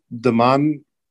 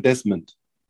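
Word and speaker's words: Demond: Und der mit der Nummer 7, DeMahn Grene, oder wie spricht man das aus? Nee, den Nachname Green, das ist wie die Farbe, aber nicht DeMahn, sondern Desmond.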